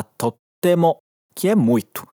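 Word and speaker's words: Tottemo (とっても): Atenção para a pronúncia aqui, que ele fala com aquele vazio de pronúncia entre o to e o te. Escuta. Tottemo. Viu? Não é tottemo, é tottemo.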